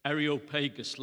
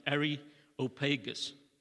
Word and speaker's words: Areopagus: And in the Greek, if we transliterate the word, it's called the Areopagus. Now, there are different ways of pronouncing this word. The British would say Areopagus, like a Cockney Irishman, Areopagus.